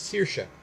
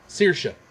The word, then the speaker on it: Saoirse: I knew as soon as I got there I would forget, because you told me what the actual pronunciation is. – Saoirse. – Saoirse. Do you get Saoirse out of that? – Yeah.